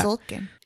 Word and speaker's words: Tzolkin: For me, so we played Zolkin. – Oh, yes. Zolkin? Is that how you say it? – I guess. T-Z-O-L-K-I-N. – It's a silent T. – To Zolkin? I don't know. I yeah. – Zolkin.